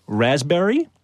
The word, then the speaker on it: raspberry: Raspberry is the silent S. – P. – Or L P. Sorry. – Silent P, right?